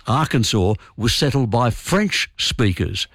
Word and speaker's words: Arkansas: So Kansas is pronounced Kansas, but the one next door, Arkansas, is pronounced Arkansas. They both come from the same Native American word, what we used to call Indian words.